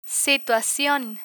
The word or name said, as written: situacion